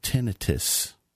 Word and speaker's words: tinnitus: Well, tinnitus, but they say you pronounce it tinnitus.